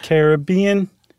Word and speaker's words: Caribbean: and this conch mollusk, the queen conch is in the Caribbean, or is it Caribbean? Because I heard someone say it once. – It's the Caribbean. Yeah, you have to say it like you're about to sing the Billy Ocean song.